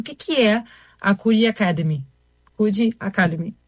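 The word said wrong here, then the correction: Codecademy: Falei certo, né? – Não. Code. – Code Academy, perfeito. – Code Academy, isso.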